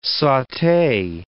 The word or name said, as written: saute